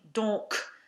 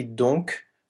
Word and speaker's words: donc: It can either be donc with the K pronounced or don with the C or the K silent. In spoken French,